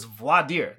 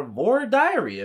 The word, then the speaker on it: voir dire: It is that voir dire is voir dire. That's how you say it. That's how you say the French words. But in Texas, it is voir dire or voir diary if you're being really crazy.